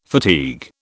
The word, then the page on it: fatigue: British English